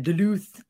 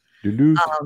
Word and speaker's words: Duluth: I was in Duluth, and you have to say it like that, Duluth. – Duluth.